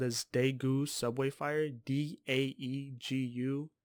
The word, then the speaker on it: Daegu: Uh, something I read was that it was formerly called Taegu. Or it was it was written Taegu, but it's still pronounced Daegu. But it was T A G T E T A E G U, but now it's D A E G U.